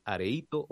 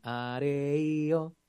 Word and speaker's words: areíto: Del areíto, o algunos dicen areío, tan, tan, tan, tan, tan, tan, pero se escribe areíto.